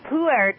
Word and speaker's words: pu'er: I'm not sure that I can tell you why you've led such a sheltered life, but pu'er tea, you actually pronounce it with two syllables.